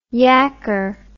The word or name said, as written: yakker